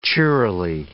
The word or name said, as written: cheerily